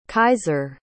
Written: kyzer

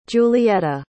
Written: julietta

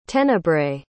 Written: tenebrae

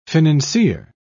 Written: financier

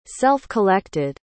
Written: self-collected

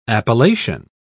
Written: appellation

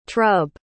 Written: trub

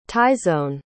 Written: tyesone

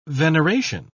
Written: veneration

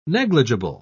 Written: negligible

